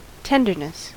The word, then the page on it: tenderness: American English